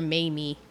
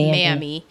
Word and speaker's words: Mammy: Which I was taught as a high school student, and it made every black character, with the exception of, I always say her name wrong, I call her Mamie, but it's Mammy.